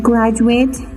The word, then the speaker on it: graduate: In the noun graduate, خب وقتی graduate به حیث اسم باشه, the last three letters sound like at in shut. سه حرف آخرش U-A-T-E مثل graduate. Graduate این قسم تلفظ میکنه مثل at تلفظ میشه مثل که لغت shut است. Graduate shut. و in the verb graduate و زمانی که graduate به حیث فعل باشه they sound like eight and late. سه حرف آخر graduate که A-T-E هستن مثل eight که در لغت late هستن تلفظ میشن.